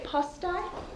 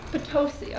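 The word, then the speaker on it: Potosí: And for those of you who know me, I have a hard time with pronunciation, so would you say it Postai? Postustai? – Yeah. Sounds good. – Postustai? Okay, this is a town in Bolivia. And Matt, pardon me? Potosi, okay, Potosi.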